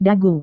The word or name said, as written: dagu